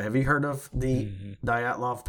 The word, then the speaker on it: Dyatlov: Dyatlov. That's how you pronounce it.